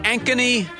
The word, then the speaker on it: Ankeny: So I looked up Ankeny, Iowa. – Because can you spell that? – A-N-K-E-N-Y.